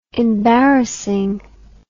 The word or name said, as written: embarrassing